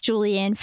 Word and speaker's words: Juliann: J U L I A N N. That sounded like end end. I promise you, it's N N.